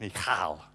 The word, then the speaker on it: Michal: I actually looked it up on YouTube on how to pronounce that name, because I was wanting to say Michael, and I thought that sounds like a man's name, but it's an Israelite name, obviously. It's Michal, but I'm not going to do that.